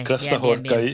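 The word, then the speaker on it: Krasznahorkai: ¿Y pronuncio bien László Krásnahorkai? – Sí, bien, vale, Krásnahorkai. Sí, el acento quizás en la O y ya está.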